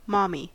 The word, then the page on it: mommy: American English